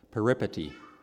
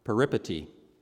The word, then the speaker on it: peripety: And as I've learned a previous time from this pulpit, for which Frank mercilessly made fun of me, you put the emphasis on the second syllable. Does that make sense? Peripety. Peripety. Peripety is a sudden and unexpected change of fortune.